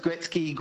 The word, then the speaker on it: Gretzky: And I'm so grateful they did because, anyway, I'm going to say it's Mr. Wayne Gretzky. – Yeah, you pronounced that just fine. Were you worried about the Wayne or the Gretzky? – Gretzky. Okay. I wasn't sure if it's Gretzky.